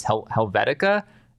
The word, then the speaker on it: Helvetica: On I think this is Helvetica.